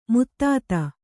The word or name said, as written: ಮದದ್